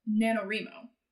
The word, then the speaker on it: NaNoWriMo: and there's two pronunciations of it because they shorten it down to the first few letters of each word. And so, as I've heard, it either I pronounce it NaNoWr-EEE-Mo, but I've heard other people or say other people have said they pronounce it NaNoWr-EEE-Mo because it's like writing, rhymo.